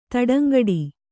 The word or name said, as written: ತಡಂಗೂಡು